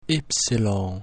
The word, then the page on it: Y: latin